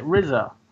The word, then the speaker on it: RZA: Only one I've got is Cutthroat City, the RZA movie set... The RZA heist movie set in the aftermath of Hurricane Katrina. – Is that how you say it? RZA? – Yeah.